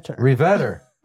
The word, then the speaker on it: Reveter: Reveter.